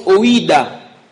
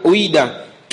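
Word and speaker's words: oïda (οἶδα): C'est un mot que l'on prononce oïda, O-I-D-A, oïda, qui veut aussi dire connaître quelqu'un intimement.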